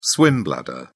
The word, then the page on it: swim bladder: British English